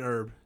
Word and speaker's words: herb: Garlic and herb by now. – Garlic and herb. – Yeah, yeah. A little bit of flavor. – With a silent H. Herb.